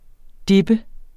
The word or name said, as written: dippe